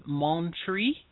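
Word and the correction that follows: Mon frère: Did I pronounce that right? – Mon frere.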